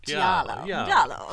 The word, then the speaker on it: giallo: Like, you see a lot of Giallo influence, and is that how you pronounce it? Giallo? Yeah, Thank you. I've only ever seen it written down. – The way they say yellow. So it's like yellow. – Giallo.